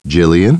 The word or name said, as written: Jillian